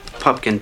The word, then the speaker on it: Pupkin: Pupkin, P-U-P-K-I-N. People often misspell it and mispronounce it. – There's a man here by the name of Rupert Pupkin who wants to see Mr. Langford. I'm sorry, Mr. Pumpkin, but Mr. Langford's secretary has no record of your appointment.